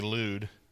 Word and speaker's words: Lud: If you look in the Old Testament, it's spelled L-U-D, Lude.